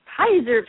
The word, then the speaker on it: Peyser: Today, we are going to take that book, and as Randy Pacer, am I saying that right, Randy? – Pizer. – Pizer, P-E-Y-S-E-R.